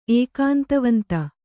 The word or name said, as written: ಏಕಾಂತವಂತ